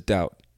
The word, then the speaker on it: doubt: And what is doubt? Maybe you don't know what that means. D-O-U-B-T, and the B is silent in this case, doubt.